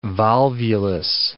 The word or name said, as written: volvulus